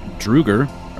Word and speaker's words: Draugr: Okay, you would know these things are called Draugr, or Draugr. I don't know how to say this word. – Spell it. – D-R-A-U-G-R. – Draugr. – I always pronounce it Draugr. – Draugr. Yeah. – I pronounce it with a German accent and say Draugr. – Draugr.